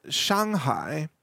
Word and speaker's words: Shanghai: And in Shanghai, which is the right way to pronounce that city in Chinese, by the way, it's over $100 an ounce for physical silver right now.